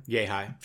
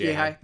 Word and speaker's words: Yehi: Fred Yehi, I'm pretty sure I said his name wrong because I'm Yehi. I'm pretty sure it's Yehi.